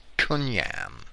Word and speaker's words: kunjam: C-Y-N, or race. In Germanic, it was K-U-N-J-A-M, and that's how he spelled it, but that J is pronounced like a Y, so it's not kunjam, it's kunyam. In English, it's shortened to kin, C-Y-N, or race, family, or kin.